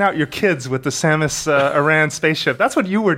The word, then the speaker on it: Samus Aran: It's pronounced Samus Aran.